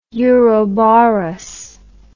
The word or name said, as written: uroboros